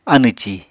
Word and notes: anẁ-ci: Apatani

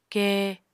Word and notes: ke: Apatani

